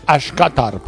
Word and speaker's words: Skottorp: Él es de Skottorp, en Suecia. – Hable bien sueco. – Perdón, sí, pero no pronuncié tan bien. Pronuncié más como danés. – Skottorp. – Skottorp. Sí, es cierto. Sí, tienes razón. Gracias.